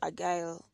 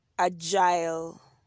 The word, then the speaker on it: agile: Is it agile or agile? No, agile. Let me just confirm that. That makes me agile. I'm big on pronunciation, man, and spelling. So, So yeah, it's agile. – Agile. – Yeah. – Agile. Agile. – Yeah, agile. All right. Yeah, I was not going to get away with the wrong pronunciation.